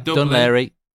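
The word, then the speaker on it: Dún Laoghaire: I know you like Ireland, so I would take you to Dunlaghair. I definitely butchered Larry. Dunlaoghair. Dunlaoghair.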